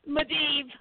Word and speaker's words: Medivh: Is his name Medivh? That was you said it right for the first time. Ooh, yo, girl. – Medivh, huh? Medivh. When Medivh died,